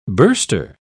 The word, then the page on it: burster: British English